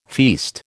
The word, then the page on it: feast: American English